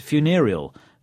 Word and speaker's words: funereal: The adjective is funereal. Notice the stress on the second syllable.